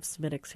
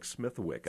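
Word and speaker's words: Smithwick's: They have Smittix here now. – So for those of us who are looking at the word and not knowing how to pronounce it, would look like Smithwick, I believe. Smithwick, yeah. That's how most people say it.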